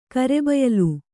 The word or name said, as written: ಕರೆಬಯಲು